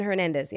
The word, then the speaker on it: Hernandez: Am I saying it right? Hernandez? – Aaron Hernandez, yes.